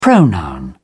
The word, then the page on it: pronoun: British English